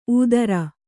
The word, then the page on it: ಊದರ: ūdara